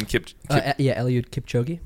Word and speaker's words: Kipchoge: Eliud Kipchoge.